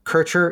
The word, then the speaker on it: Kircher: K-I-R-C-H-E-R. – Yep. – So Kircher, exactly what you would think. Don't try to add a T. – Yep. Right? Or a Ner. There's no Kirshner. It's Kircher.